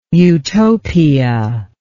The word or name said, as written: eutopia